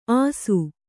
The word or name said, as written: ಆಸು